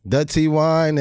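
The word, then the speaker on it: Dutty Wine: When you learn how to dirty wine, I'm saying it right? – Dutty wine and do all that shit,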